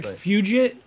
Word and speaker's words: Fugit: No, Patrick Fugit? Am I saying that right? – Yep, that's it.